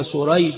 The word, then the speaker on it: سريج: وأما اسم الأبوين فقد اتفقا خطا ونطقا، النعمان والنعمان، ولكن شريح بن النعمان وسريج بن النعمان، الأول بالشين المعجمة والحاء المهملة وهو تابعي يروي عن علي رضي الله عنه، والثاني وهو سريج بن النعمان بالسين المهملة والجيم، هو من شيوخ البخاري، فهو النوع الذي يقال له المتشابه.